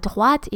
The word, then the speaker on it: droite: And droite is spelled D-R-O-I-T-E. Yes, because I sound the T. You hear when I say droite or à droite, I sound the T. Therefore, it's not final. It is followed by an E, which is not pronounced.